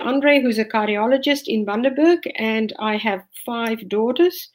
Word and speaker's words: Bundaberg: and we found out that it's pronounced Bundaberg.